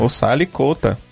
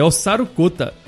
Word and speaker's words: Osaru Kota: Osali Kota. – É, Osaru Kota. Escreve-se Osali, né? Isso. E pronuncia-se, pelo japonês, como Osaru. Osaru Kota.